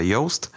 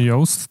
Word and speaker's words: Yoast: która się nazywa Yoast pisze się y OAST, y Yoast SEO, mm, która ułatwia prowadzenie y, własne, własnoręczne prowadzenie pozycjonowania strony www.